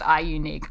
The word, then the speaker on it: iUNIK: So that's spelled I-U-N-I-K. They're doing a little bit of a funky thing with their letters there, but it is pronounced iUnique, I am told.